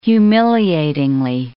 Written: humiliatingly

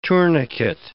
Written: tourniquet

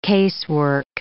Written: casework

